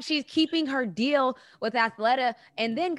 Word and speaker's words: Athleta: How do you say that? Athleta? Athleta? Yeah. – Athletica. – No. – No, Snook. Athleta.